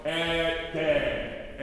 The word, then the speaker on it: et de: Et de, oh, by the way, I don't separate et and de here. We go et de. Et de. Don't pronounce the T. Just throw the T away and focus on the D.